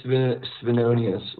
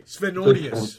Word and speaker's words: Svenonius: I got Ian Svavonius. How do I pronounce it right? – It's Svenonius, like Svengali. – Okay, Svenonius.